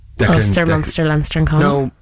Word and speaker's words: Connacht: Ulster, Munster, Leinster and Connacht.